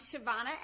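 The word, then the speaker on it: Chavonna: I'm Shyvana Adams. That's spelled C-H-A, V as in Victor, O-N-N-A, last name Adams.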